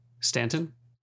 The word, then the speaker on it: Stanton: which was directed by Andrew Staton. Staton. – Is that really how you pronounce it? Staton? – Stanton? I guess it's Stanton.